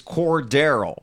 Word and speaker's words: Cordarrelle: Alan Saunders did it phonetically as Cordarrelle. Like Cordarrelle. – Darrelle with core in front of it.